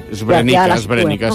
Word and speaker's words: Srebrenica: L'Aida treballa com a traductora per a la ONU a la petita ciutat de Srebrenica. No sé pronunciar, Srebrenica o... Bueno, Srebrenica, sí.